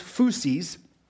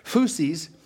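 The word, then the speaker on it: phusis (φύσις): This Greek word, this one here, which is pronounced phusis, kind of a weird word, right? Phusis. This word phusis carries with it an idea, not of just nature, but it also carries the idea of birth and origin or original nature.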